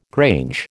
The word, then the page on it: grange: American English